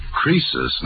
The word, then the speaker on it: Croesus: Chrysler was a rich king. – Oh, don't you know, Susie? Croesus, not Chrysler, was a rich king.